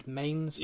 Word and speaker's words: Mainz: I don't know how to pronounce it. M-A-I-N-Z. Mainz? – Yeah, I think it's Mainz, yeah.